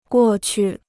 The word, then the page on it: 过去: guò qu